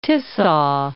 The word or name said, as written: Tisza